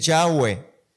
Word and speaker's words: Yahweh: Jehová no existe, no existe. Es Yahweh. Yahweh. Yahweh, Yahvé, pero, pero no se puede pronunciar.